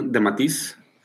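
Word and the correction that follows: DeMatteis: De Matéis. El guion es de Matéis, pero dice que supuestamente el plot es de Tom DeFalco.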